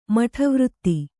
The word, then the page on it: ಮಠವೃತ್ತಿ: kannada